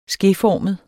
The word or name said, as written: skeformet